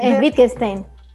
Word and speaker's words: Wittgenstein: No, Wittgenstein. – ¿Wittgenstein? Sí, como el filósofo.